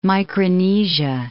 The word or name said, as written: Micronesia